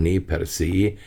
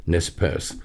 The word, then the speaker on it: Nez Perce: Die Nez Perce, richtig eigentlich gesprochen Ne Perce, die durchbohrten Nasen, amerikanisch aber halt dann immer Nez Perce ausgesprochen,